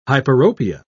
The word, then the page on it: hyperopia: British English